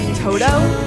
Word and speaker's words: Toto: Toto.